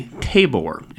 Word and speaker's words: tabor: called a tabor, and I'm absolutely pronouncing that correctly.